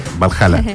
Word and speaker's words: Valhalla: ¿Lo dije bien? – Sí, Valhalla.